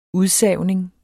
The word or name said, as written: udsavning